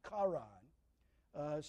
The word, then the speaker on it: Charan: That sounds like the brother's name, but it's actually a different word. You might spell it C-H-A-R-N, Charan.